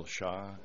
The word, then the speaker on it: Shaw: This is Bill Shaw, SHAW, Project Manager for the Milwaukee County Automated Mapping and Land Information System.